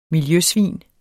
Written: miljøsvin

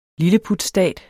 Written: lilleputstat

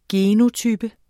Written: genotype

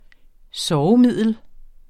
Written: sovemiddel

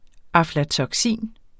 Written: aflatoksin

